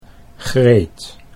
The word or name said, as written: Greet